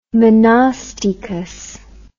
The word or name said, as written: monostichous